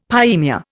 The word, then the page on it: pyemia: Indian